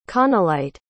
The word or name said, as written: connellite